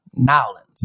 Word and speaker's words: Nawlinz: I know it's spelled N-A-W-L-I-N-Z. Nowlands.